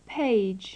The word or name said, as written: page